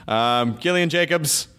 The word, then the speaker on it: Gillian Jacobs: Gillian Jacobs, hard G, soft J. I know all J's are soft, but I just feel like it's the way you're supposed to. Hard G, soft J sounds really cool.